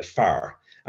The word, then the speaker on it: fear: One interesting part of that was in the Irish language, the Irish word for a man, a man is a far, and it's spelled F-E-A-R. Which is the same as fear,